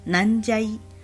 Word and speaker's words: 南蛇井: 好，光看中文没有感觉到它有什么哪里有趣的地方，嗯、但是呢，它的读音念作南加一。就是嗯，该怎么讲呢？南加一就是听起来有点像是在搞笑，有点有点像是一个梗那种感觉，就是有一种什么呢的那种感觉的意思。嗯、好，南加一，然后所以呢，呃，就，就如果有人问你说，哎，你住哪里啊？